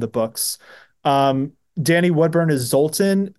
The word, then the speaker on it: Zoltan: Am I saying that right? – Yeah, Zoltan. Okay.